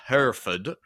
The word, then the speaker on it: Hereford: And then through a hamlet with the lovely name of Dawn, a small town called Hereford, which actually I think is pronounced Hereford around those parts,